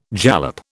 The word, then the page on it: jalap: British English